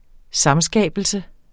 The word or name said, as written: samskabelse